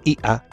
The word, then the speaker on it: ja: En alemán es ja, que se escribe ja, pero se pronuncia IA.